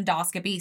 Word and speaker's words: endoscopy: And so there was, and they did an endoscopy. See, I said it right that time. – There you go. – An endoscopy, and everything was fine.